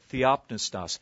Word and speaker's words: theopneustos: Theopneustos, with the accent on the second syllable there, theopneustos.